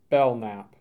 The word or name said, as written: Belknap